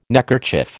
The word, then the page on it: neckerchief: American English